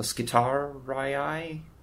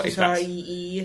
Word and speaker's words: Skitarii: I've always said Skitari, but I've been told that a double I at the end of a Latin name is stupid. – You're supposed to pronounce both of the I's.